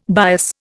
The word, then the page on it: bias: Indian